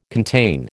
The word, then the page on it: contain: American English